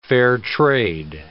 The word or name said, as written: fair-trade